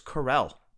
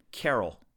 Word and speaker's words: Carell: Jeff, it's Carell, not Carroll. – You son of a... Anyways. – I know. Look, people get mad. They grab their pitchforks and torches. They get very angry when you mispronounce his name.